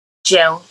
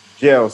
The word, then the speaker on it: Jeun: Jeun. – Jeun. So how do you spell that? – J-E-U-N. – J-E-U-N.